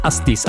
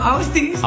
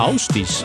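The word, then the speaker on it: Ásdís: von insgesamt 10 Hits am Stück hier auf Krone Hit ist Flashback von einer Sängerin aus Island, die geschrieben wird A-S-D-I-S, Astis. Aber ausgesprochen wird's? – Hi, I'm Austis. – Austis.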